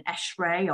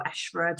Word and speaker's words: ESHRE: finding out if they have members within ESHRE or ESHRA, depending how you pronounce it,